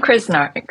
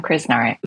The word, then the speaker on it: Krznaric: Roman Krznaric. Yeah, Roman Krznaric.